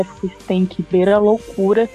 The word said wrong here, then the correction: Heathcliff: Só lembrando que, só, só para ajudar, uh, pronuncia Heathcliff e das músicas fica bem ritmo. Tipo, Heathcliff, isso. É, Heathcliff.